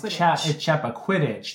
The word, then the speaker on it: Chappaquiddick: Quidditch or Chappaquiddick? – It's Chappaquiddick.